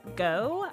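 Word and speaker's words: Goux: He currently has a highly anticipated graphic novel called Forest Hills Bootleg Society, and he co-wrote it with Nicole Gough. I do believe that's how it's pronounced because it's G-O-U-X. And if I'm thinking of like French and then French Creole, I'm just like, that sounds like go.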